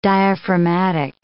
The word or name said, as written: diaphragmatic